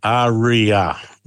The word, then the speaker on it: Ariya: This is the Nissan Ariya. Have I pronounced that correctly? – Yeah, Ariya, yeah. And it's spelled A-R-I-Y-A, a name that sort of flows off the tongue.